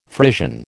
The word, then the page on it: Frisian: American English